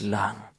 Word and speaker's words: Mulan: Mulan.